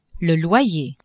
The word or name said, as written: loyer